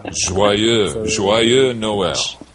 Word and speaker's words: Joyeux Noël: The movie guy says, Joyeux Noël. I know I'm saying it wrong. – Joyeux Noël.